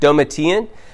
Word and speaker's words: Domitian: I had to learn that pronunciation last night. Domitian.